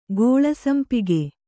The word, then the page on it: ಗೋಳಸಂಪಿಗೆ: gōḷa sampige